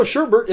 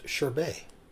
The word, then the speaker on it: sherbet: And you know, Sherbert isn't even spelled Sherbert. It's Sherbet. And it's probably pronounced Sherbet.